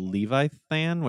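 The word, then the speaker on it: Levithan: I thought it was Leviathan, but it's spelled Levi-than, which I don't know what that is.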